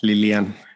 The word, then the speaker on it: Lilian: ¿Qué tal, Lilian? Eh, muy bien, gracias. ¿Cómo estás tú? – Bien, es muy raro que me digan Lilian. Eh, lo pronunciaste muy bien, de hecho mi nombre así se pronuncia porque por lo general me dicen diferente y me dicen Lilo, pero soy yo demasiado así como formal el asunto.